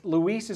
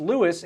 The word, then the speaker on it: LUIS: You can even go out to Cortana or Siri and say, how do I pronounce L-U-I-S? And it'll tell you how to pronounce that if there's any questions. So if you guys are out there saying it's Lewis, Lewis, Lewis, it's not. It's Luis.